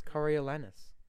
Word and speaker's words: Coriolanus: I never know how to pronounce his name. Who would become the dictatorial president. No, it's Coriolanus. They all have weird names. – It's like, Cornelius. – No, it's not. It's literally spelled Coriolanus.